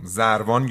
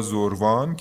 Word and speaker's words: زروان: زروان یا زروان که هر دو تلفظش در اوستا هم ذکر شده، خدای زمان، خدای مطلق زمان و مکانه.